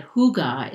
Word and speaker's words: hygge: It's spelled H-Y-G-G-E, but it's pronounced hygge, like being wrapped in a soft, warm blanket.